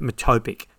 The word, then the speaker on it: metopic: Another type of craniosynostosis, which is the second most common type of single suture craniosynostosis, is called metopic craniosynostosis, although some surgeons pronounce it metopic craniosynostosis.